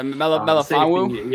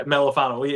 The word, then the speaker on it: Melifonwu: You know, I mean, we swung and missed early on, I can't pronounce it, Iwafana Mentu or whatever. – Yeah, Melifonwu. – Yeah, Melifonwu.